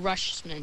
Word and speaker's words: Rutschman: I really am excited to see, though, Adley Rutschman. I believe I said that right. – Yeah, you did.